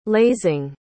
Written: lazing

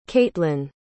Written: caitlin

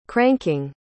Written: cranking

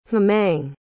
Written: hemang

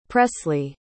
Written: presley

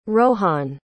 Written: rohan

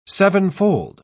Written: sevenfold

